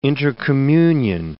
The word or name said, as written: intercommunion